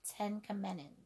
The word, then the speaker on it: Tenkamenen: King Ten Ten Tenkamenen, that's how you say his name.